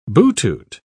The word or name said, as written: butut